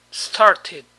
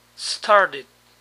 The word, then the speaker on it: started: En el pasado, started o started. Para pronunciar la segunda forma, es bueno imaginar que la T suena como nuestra D, aunque un poco más suave. Started. Started.